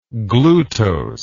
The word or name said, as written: glutose